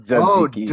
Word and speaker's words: tzatziki: For more thoughts on Take Care, and Tzatziki Sauce, did I say it right? – Tzatziki Sauce, yeah. – Tzatziki, like two Z's? – It's like a DZ, like Tzatziki.